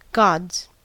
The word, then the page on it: gods: American English